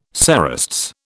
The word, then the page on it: cerastes: British English